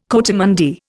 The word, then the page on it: coatimundi: Indian